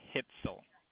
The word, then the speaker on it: Hitzel: Yep, it's Francis Hitzel, H-I-T-Z-E-L. – Francis Hipple. Okay, just a moment. – The last name is spelled H-I-T-Z-E-L.